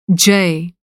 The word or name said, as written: जय